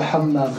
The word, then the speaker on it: hammam: The hammam.